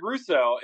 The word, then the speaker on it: Rousseau: R-O-U-S-S-E-A-U. – Yeah, see, I was going for that ew sound at the end. – You go for that junior sound. – Ew. – I'm sitting here like, R-U-S-S-O, Russo, yeah.